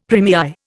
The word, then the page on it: première: Indian